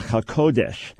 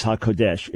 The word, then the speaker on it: HaKodesh: Now, people may be familiar with the term Ruach HaKodesh. And by the way, for the benefit of our listeners, it's often mispronounced as HaKodesh. It's Ruach HaKodesh, emphasis on the ko.